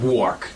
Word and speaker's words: Wuark: How do you pronounce that? – Wark. W-U-A-R-K.